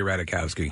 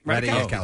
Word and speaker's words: Ratajkowski: I've never really fully learned how to pronounce her last name. Yeah. I always say Ratajkowski. – I did. Yeah. What? That's right. – Ratajkowski.